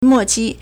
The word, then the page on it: 末期: mòqī